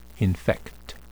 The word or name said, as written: infect